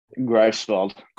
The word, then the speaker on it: Greifswald: Greifswald. – Greifswald, of course. Yeah, the I before the E.